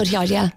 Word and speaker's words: Cotillard: I think Marion Cotillard, is that how you say her name? – Yes, Cotillard, I quite like that.